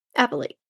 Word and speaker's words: appellate: That's the proper pronunciation. – Of appeals. That's what we're going to call it from here on out. – No, I know how to say it now. Appellate. – No, I'm still going to call it the Court of Appeals. – That's fine. You also mispronounced it the same way, though. – Yeah. – How did I pronounce it? – Appellate. – Appellate. The appellate court. No, it's appellate. Appellate court.